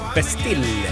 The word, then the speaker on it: Bastille: Bastille ska det uttalas.